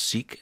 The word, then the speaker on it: Sikh: And Sikh is spelled S-I-K-H. – Yes. – We're as likely to hear it pronounced Sikh as Sikh, which is actually correct. – Actually, it's Sikh.